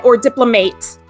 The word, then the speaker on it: diplomate: So in order to become a diplomate, I've learned is the proper pronunciation.